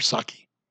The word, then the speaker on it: Psaki: So the White House spokesperson, her name is spelled P-S-A-K-I, but I guess we just call her Saki. Peace silent. Saki.